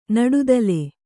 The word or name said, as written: ನಡುತಲೆ